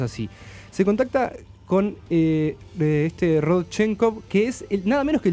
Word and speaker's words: Rodchenkov: Rodchenkov.